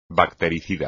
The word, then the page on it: bactericida: espagnol